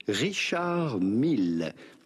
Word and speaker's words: Richard Mille: Do not pronounce the D to Richard. It's not like in English, you do not say Richard, but rather Richard. In English, Richard Mille. Do pronounce the double L. Richard Mille, with the typical French or Swiss French pronunciation, Richard Mille.